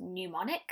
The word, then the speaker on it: pneumonic: The two other forms are, I believe it would be pronounced pneumonic, like pneumonia, and septicemic.